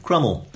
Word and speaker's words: Cromwell: And incidentally, gave me another clue to the pronunciation of Crummel. Because on more or less day one of the rebellion, some rebels in Dent shouted that we would crumb him and crumb him till he was never so crumbed.